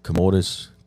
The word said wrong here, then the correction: commotio cordis: Commotio cordis.